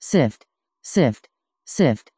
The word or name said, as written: syft